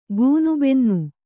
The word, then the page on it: ಗೂನುಬೆನ್ನು: Kannada